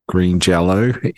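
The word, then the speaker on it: Green Jellÿ: So they changed it to Green Jelly, but they put the umlauts over the Y, and he says that means you pronounce it as Green Jello, even though it's pronounced written as Green Jelly.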